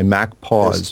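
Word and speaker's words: MacPaw: Clean My Mac is the name by Mac Pause. Yes. Very good. – Doesn't rhyme with Mac Reaper.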